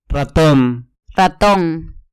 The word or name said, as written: raton